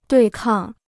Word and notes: 对抗: duì kàng

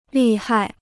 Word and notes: 利害: lì hai

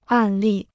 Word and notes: 案例: àn lì

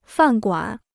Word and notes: 饭馆: fàn guǎn

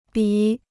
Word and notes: 笔: bǐ

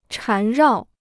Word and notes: 缠绕: chán rào

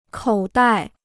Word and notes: 口袋: kǒu dài

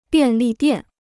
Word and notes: 便利店: biàn lì diàn

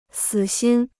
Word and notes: 死心: sǐ xīn

